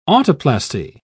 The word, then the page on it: autoplasty: British English